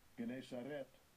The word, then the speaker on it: Gennesaret: Strong's G, 1082, Gennesaret.